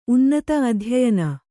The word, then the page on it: ಅಧ್ಯಯನ: adhyayana